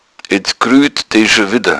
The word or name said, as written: gruehen